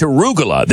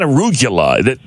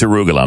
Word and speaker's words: arugula: But man, it's arugula. It's not arugula. He's adding syllables.